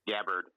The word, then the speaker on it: Gabbard: Gabbard.